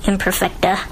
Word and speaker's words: imperfecta: And so you were born with a thing called osteogenesis imperfectus. Did I say this right? – Close. Imperfecta.